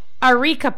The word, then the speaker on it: areca: areca palm. It's spelled A-R-E-C-A, but it's pronounced areca, not areca.